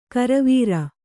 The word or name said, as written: ಗುರುವಾರ